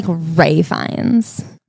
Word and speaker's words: Fiennes: I think that's not how you say it. – Fiennes? – I think it's like Ray Fiennes. Riffin.